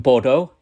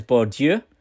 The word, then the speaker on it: Bourdieu: So I always pronounce this philosopher wrongly. I did a talk in Hull York the other day and I called him Bordeaux, and I think his name is Bourdieu.